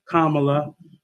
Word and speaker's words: Kamala: Why do I keep saying her name wrong? Kamala.